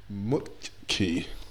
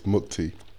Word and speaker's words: Mukti: And the next game, Mukti. I've said that wrong. I'm going to repeat that again. It's Mukti.